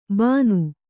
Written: ಬಾನು

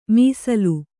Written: ಮೀಸಲು

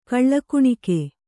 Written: ಕೊಳಗುಣಕೆ

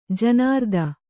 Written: ಜನಾರ್ದನ

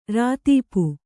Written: ರೈತಾಪಿ

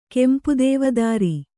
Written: ಕೆಂಪುದೇವದಾರಿ

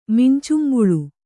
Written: ಮಿಂಚುಂಬುಳು

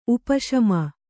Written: ಉಪಶಮೆ

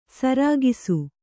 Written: ಸರಕ್ಷ

